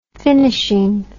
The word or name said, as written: finishing